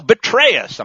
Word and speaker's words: Petraeus: I read that four-star general Petraeus, and I think that's the proper way to pronounce it, folks, one of the most stupid of Washington's political generals, has declared that Putin is desperate.